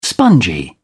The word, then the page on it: spongy: British English